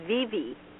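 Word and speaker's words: Vevay: Which is spelled Vive, but... I don't know why. When you go into town, you know, and say something about Vive, and the locals will say, it is Vivi.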